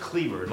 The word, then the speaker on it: Kliewer: D-A-V-I-D for David. Cleaver is K-L-I-E-W-E-R. – Pronounce Cleaver. – It's pronounced Cleaver, like Eldridge Cleaver, but it's spelled differently.